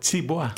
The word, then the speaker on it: tibwa: And it's called tibois. – Tibois. – That's interesting. – T-I-B-W-A. – Okay. – Tibois. – Okay. Bois, just as it sounds, phonetically.